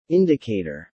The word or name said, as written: indicator